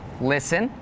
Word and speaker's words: listen: Some examples with a silent T are the words listen, the word castle, and the word Christmas.